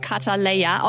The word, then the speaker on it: Cutaleya: Samra eröffnet heute in Berlin den Barbershop Kataleya, ausgesprochen wie seine Goldsingle, aber geschrieben mit Cut für Haare schneiden.